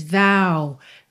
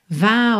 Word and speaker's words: vow: Let's take a lover's vow. Vow, atenção aí à pronúncia dessa palavra que é V-O-W. Que esse O vai ter esse som mais aberto, tá?